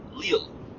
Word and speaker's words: Lille: Lille.